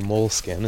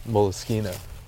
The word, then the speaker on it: Moleskine: Or Moleskine, as some people say, but the correct pronunciation is Moleskine.